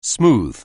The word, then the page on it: smooth: anglais